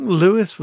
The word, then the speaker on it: Louis: I think Louis was how you prefer to be referred. I'm a Louis with a silent S. So I always call him Louis Armstrong.